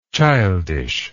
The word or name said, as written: childishness